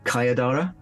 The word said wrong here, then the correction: Sayadara: Sai. – Did I pronounce it? – Sayadara. – Sayadara.